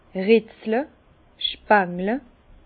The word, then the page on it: retzle: Bas Rhin; Herrlisheim